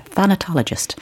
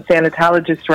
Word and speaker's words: thanatologist: And there is one that takes place regularly in Limerick, and it is hosted who is, if I'm correct in saying this, a thanatologist. So welcome to the show. – Hi, thank you, and thank you. Well done you for pronouncing thanatologist right, and I appreciate that, so gold star.